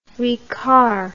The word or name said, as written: ricard